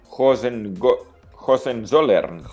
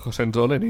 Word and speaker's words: Hohenzollern: Sí, unes vistes directes cap al pont Hohenzollern. – Hohenzollern, i tant que sí.